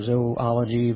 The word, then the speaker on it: zoology: We get the English words zoo and zoology or zoology would be proper pronunciation, from the root word of this.